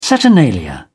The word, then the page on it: saturnalia: British English